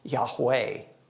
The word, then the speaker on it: Yahweh: which is impossible to actually pronounce correctly because it's all consonants. And so the Jews, in order to pronounce that name, gave it vowel sounds. And so typically it is pronounced Yahweh.